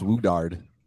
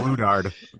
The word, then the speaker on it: Woodard: I've gotten a lot of misspellings and a lot of mispronunciations. – Yeah. – Woodward being the biggest, I get Woolard Waters. – I always assumed it was pronounced Woodard.